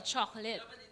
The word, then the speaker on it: chocolate: Yeah, but you're not emphasizing every syllable, chocolate.